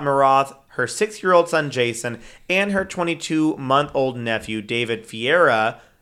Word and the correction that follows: Ferreira: Ferreira. I think it's Ferreira. – Ferreira. Ferreira. – Because Sky Ferreira's name is spelled that way. And she's a singer.